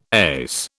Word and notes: ace: British English

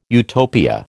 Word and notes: Utopia: American English

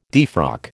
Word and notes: defrock: American English

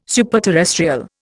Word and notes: superterrestrial: Indian

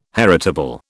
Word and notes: heritable: British English

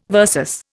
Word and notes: versus: Indian

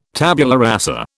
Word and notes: tabula rasa: British English

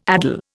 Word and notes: addle: Indian

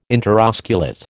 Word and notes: interosculate: American English

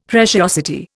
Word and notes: preciosity: Indian

